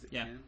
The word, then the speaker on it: Cannes: Am I saying that right? Cannes? – Yeah.